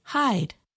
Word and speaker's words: hide: You asked about the word hide. That has a silent E because at one time there were two syllables or separate parts to the word. In Middle English, one might say hida.